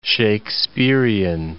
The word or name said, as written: Shaksperean